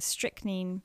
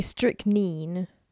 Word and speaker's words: strychnine: It can be strychnine. Strychnine. Or strychnine in the US. – Okay, so it's accurate for me to say strychnine.